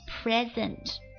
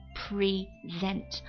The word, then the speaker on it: present: 接着往下看 present 名词礼物现在，以及形容词出席的、当前的，还有动词 present 提出、赠送的意思。注意一下名词和动词读音是不一样的，名词或者形容词读作 present，而它作为动词的时候叫读作 present。